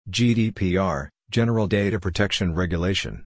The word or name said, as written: GDPR (General Data Protection Regulation)